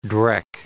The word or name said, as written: dreck